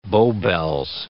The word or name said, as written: Bow bells